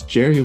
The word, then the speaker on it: Gerry: Gerry Lester Watson Jr. Yeah, it's Jerry with the G. I don't think it would be pronounced Gary. I think it's Jerry.